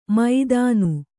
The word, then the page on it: ಮೈದಾನು: maidānu